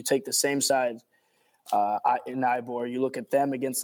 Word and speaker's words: Eibar: So that team would be, in this case, Eibar. I'm not sure if I'm pronouncing that. Yeah, Eibar.